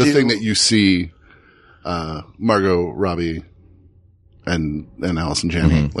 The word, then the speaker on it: Margot Robbie: Margot Robbie would be easier to say. Long O's. Just do Margot Robbie. Margot Robbie.